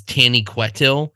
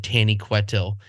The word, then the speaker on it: Taniquetil: I remember I watched somebody do a scathing critique of anybody that referred to the mountain that Manwë lives on as Tani Quetil. And they were really mad at the guy that read the audiobook because he said Tani Quetil. And it's pronounced Taniquetil.